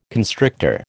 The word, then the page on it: constrictor: American English